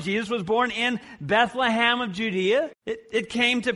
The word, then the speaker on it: Bethlehem: Bethlehem! You even pronounced it right.